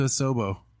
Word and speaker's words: Asobo: So shout out to Asobo.